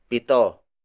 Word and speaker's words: pito: Up next is pito.